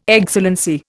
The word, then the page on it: Excellency: Indian